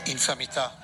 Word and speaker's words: infamità: So this is the Italian pronunciation. – Infamità. – Infamità. – Did you hear that, Alex? – Mm-hmm. Infamità. – Uh-huh. That's it. – Infamità. – He puts the emphasis on the fam, which makes sense if it's family.